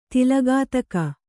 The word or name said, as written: ತಲೆಗುತ್ತುಗೆ